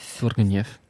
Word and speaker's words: Tourgueniev: Et euh, je vide certaines affaires et je retombe sur un livre, notamment qui s'appelle Premier amour de Ivan Tourgueniev. Je ne sais pas si je le prononce correctement parce que la personne est russe. Mais ah. voilà. Je pense que c'est Niev. Niev, ouais. Tourgueniev.